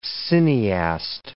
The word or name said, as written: cineast